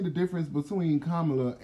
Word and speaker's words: Kamala: Okay, okay, so he's the elect, and then Kamala. – Yes, I said her name right.